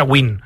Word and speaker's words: Nguyen: Muchos jugadores americanos vietnamitas tienen esa, pero se pronuncia Win. Se pronuncia W-I-N, se pronuncia Win. Sí. Por ejemplo, Scotty Nguyen, que ha sido campeón del mundo, que es el Nguyen más famoso que hay, sí. Es Scotty Win, porque se pronuncia Win. Pero se escribe así, N-G-U. Y-E-N, Nguyen.